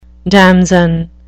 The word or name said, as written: damson